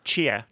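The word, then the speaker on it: Tchia: Tia? – Chia. Just imagine the T is silent. Chia.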